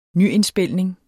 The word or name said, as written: nyindspilning